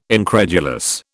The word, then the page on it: incredulous: British English